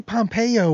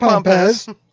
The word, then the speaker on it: Pompez: I always want to say Pompeo or something instead of Pompez. Yeah. Like, I think there's going to be a third syllable. Guess what? There's no third syllable.